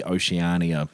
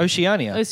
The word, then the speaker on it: Oceania: Even on like the Australia or like the Oceania yep. – service. I've never known how to pronounce that word. – Oceania.